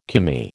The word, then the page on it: Commie: American English